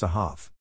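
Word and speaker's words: ahav (אהב): To love is the three-letter Hebrew verb root ahb, pronounced ahav.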